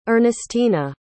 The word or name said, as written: Ernestina